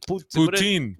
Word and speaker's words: poutine: How do you say it, Dave? – I say poutine, but the French poutine. – Poutin. – Poutin. We see a poutin.